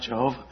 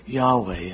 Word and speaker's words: Yahweh: and that's where they got the pronunciation Jehovah, using the vowels from Adonai. Then somebody realized, well, the reason they put the vowels in there was because they pronounce it with Adonai and not Jehovah. And actually the pronunciation would be Yahweh. And that's why you'll see in the more recent ones, they'll say Yahweh instead.